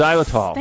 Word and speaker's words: xylitol: Xylitol? – That's it. – Yes. – Xylitol.